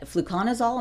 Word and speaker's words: fluconazole: The treatment itself, that fluconazole, am I saying it right? – Mm-hmm.